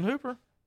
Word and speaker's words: Hooper: Austin Hooper. – Who is yes, it's Austin Hooper, right? Yeah, I want to yeah. say it was Hopper, but I, Hooper, Austin yeah. Hooper.